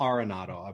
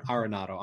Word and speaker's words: Arenado: Arenado, I believe is how I'm. Ar- not Arenado, Arenado. Uh, so uh, oh, apologies to Nicole. I'm playing Aaron Arenado.